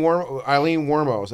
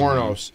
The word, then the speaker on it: Wuornos: You know Eileen Wuornos, that's how you pronounce it. – Wuornos.